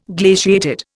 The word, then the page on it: glaciated: Indian